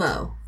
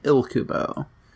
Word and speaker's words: Ilkubo: There's an L after the I. – Huh. so, like, if you just did a generic pr- pronunciation, it'd be like Ilkubo. – Ilkubo. – But I think it's supposed to be pronounced Ikubo.